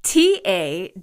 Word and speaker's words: Ta-Nehisi: The journalist Ta-Nehisi Coates, T-A-N-E-H-I-S-I, pronounced Ta-Nehisi.